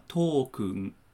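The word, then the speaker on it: Token (トークン): How do you say that in Japanese? – Token. – Token? – Yeah. – Token. – So it's basically almost the same as the name English.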